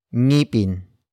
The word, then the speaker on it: ngipin: And it was ngipin. Ngipin. Ngipin. Ngipin is spelled as ng. I. P. I. N.